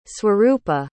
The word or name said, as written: swarupa